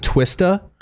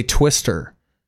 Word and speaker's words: Twista: And I used to say Twister and I'd get made fun of because his name is spelled T-W-I-S-T-A. – It ends in an A. – Like they would say like, dude, it's Twista. Like say it like that.